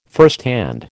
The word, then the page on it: firsthand: American English